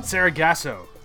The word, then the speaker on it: Sargasso: How do you pronounce the name of the episode? The next week? – Ghosts of Sargasso? – Sargasso. – I believe that's correct, yeah.